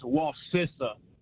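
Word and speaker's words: Wacissa: It's Wasissa. I grew up in that area. – Wasissa. Like sister. Wasissa. Hey, my Wasissa.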